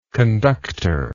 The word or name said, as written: conductorship